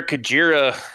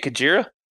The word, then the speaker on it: Kujira: Hopefully our Kujira, or how do we say it? Kujira? Kuji? – I call it Kujira.